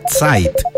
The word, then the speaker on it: Zeit: Y se pronuncia Zeit. Zeit. Así, la Z es como un. Ah, de caso que está. – Como una T, como que tuviera una T ahí al principio.